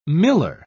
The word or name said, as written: miller